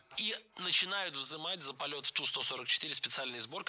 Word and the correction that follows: взимать: Но меня смутило слово «взимать», потому что так говорить не рекомендуется. Правильно, «взимать». С гласной «и» в первом слоге мы и пишем, и произносим это слово «взимать».